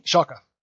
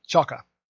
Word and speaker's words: Schalke: Is that pronounced Schalke? – Schalke. – Schalke? – Schalke. – One more time.